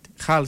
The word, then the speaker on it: Hull: Χουλ, Χαλ, συγγνώμη, Χαλ Σίτι. Άλλοι τι προφέρουν, Χουλ, άλλοι Χαλ. – Χαλ. Χουλ Σίτι. Χαλ.